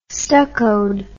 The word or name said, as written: stuccoed